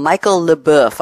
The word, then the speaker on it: Leboeuf: Well, I have a very interesting quote from Michel Leboeuf. I'll pronounce it that way, the French way.